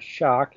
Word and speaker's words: Schoch: And so people know my name is Robert Schoch, and the last name is spelled S-C-H-O-C-H.